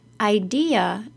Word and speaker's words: idea: First, the word is three syllables long. I-D-E-A, idea. If you're saying it as two syllables, it will be very hard for people to understand what word you're saying.